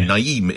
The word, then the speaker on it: Naeem: Naeem. Am I saying that correctly? – Naeem. – I'm sorry. Naeem in Leytonstone.